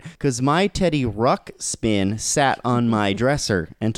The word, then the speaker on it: Ruxpin: It's Ruxpin. It's not Rubskin.